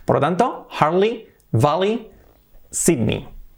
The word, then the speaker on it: Sydney: Al menos aquí en España decimos siempre Sydney, y no es Sydney, es Sydney. Sydney. ¡Wow! Sydney. Sydney, con I.